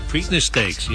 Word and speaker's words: Preakness: The Preakness? Preakness? – The Preakness. Yeah, Preakness. Preakness Stakes.